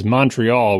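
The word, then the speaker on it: Montréal: The one I learned, of course, is Montreal with the first syllable sounding like the word hunt, but with an M in front of it.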